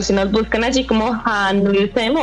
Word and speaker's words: HANNEULSSEM: La pronunciación correcta es Hanulsem, pero pues así nos van a encontrar en todo lado.